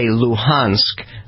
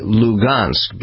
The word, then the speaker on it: Luhansk: the Ukrainians say Luhansk, the Russians say Lugansk, because there's not an H sound in Russia.